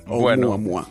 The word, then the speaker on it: Oumuamua: Pues muy bien, muy bien pronunciado en perfecto hawaiano. – Oumuamua.